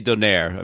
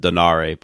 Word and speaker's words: Donaire: So getting back to the World Boxing Super Series for a second, I mean, obviously both the Inoue, Donaire, I always say Donaire. I mean, I've heard people pronounce it Donare, but I'm going to say Donaire.